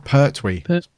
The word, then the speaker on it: Pertwee: She hates me saying Pertwee. Right, Pertwee. – Pertwee. – Yeah, I have to clearly pronounce the T.